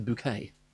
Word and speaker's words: Bucket: The woman, Hyacinth, She's Hyacinth, her last name is Bucket, but she refuses to be called that. She insists it be pronounced a bouquet, mm-hmm.